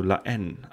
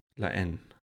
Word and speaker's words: La Haine: Ich würde sagen, La Haine. – Ja, ich würde sagen, es ist ein stummes H, also La Haine, aber... – La Haine? – La Haine, ja.